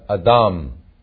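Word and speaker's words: adam (אדם): Adam 啊，看看这个，这个是 close 还是 open syllable？ close 很明显，对不对？子母子，但是你发现它这里呢是个读 adam，这个是一个长母音，因为它的重音这里有个重音符号，看到书吗？adam。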